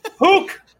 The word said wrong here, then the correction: Houck: Tanner Hook. – No, it's Tanner Houck, man.